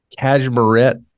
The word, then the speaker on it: Cashmerette: I've got the Cashmerette patterns. Did I say that right? – Yep.